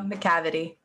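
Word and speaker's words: Macavity: Why do you keep saying McCavity? – Am I saying it wrong? – I think it's McCavity, right? – McCavity. – There's no R in that second section.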